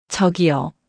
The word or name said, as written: 저기요